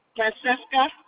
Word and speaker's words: Francesca: Francesca. – And could you spell that for me? – F-R-A-N-C-E-S-C-A.